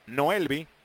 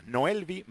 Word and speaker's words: Noelvi: Noelvi con V labio dental, V chica y latina. Noelvi Marte. Acentuando la última sílaba.